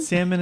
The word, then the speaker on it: salmon: It's not Solomon. It's not Saruman. – Salmon. – Salmon. – Salmon. – Salmon. Hang on. Neil's a target. – And welcome to the fish house. What would you like? I would like some salmon. – I don't like that salmon. – That's what I'm, but I don't understand. How do you say it? Say it. – Salmon. – Salmon. There's no L. – Salmon. – It's S-A-L-M-O-N.